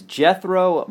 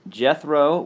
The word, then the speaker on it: Gethro: His name is Jethro Muscadin. Jethro, which is spelled G-E-T-H-R-O.